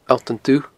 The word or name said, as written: Allt an Dubh